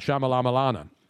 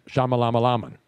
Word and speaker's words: Shyamalan: Ken, you didn't say it right. You have to say it like Tony. – I'm sorry. – M. Night Shyamalan. Shyamalan.